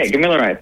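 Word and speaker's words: Gamilaraay: Is that how it's pronounced? – Yeah, Gimilaray. Perfect. Yep. So it's Gimilaray.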